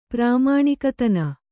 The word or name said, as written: ಪ್ರಾಮಾಣಿಕತನ